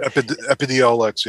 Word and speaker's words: Epidiolex: So that's the process that companies have to go through to get a drug like Epidiolex. I don't know how you say it, but Epidiolex, yeah.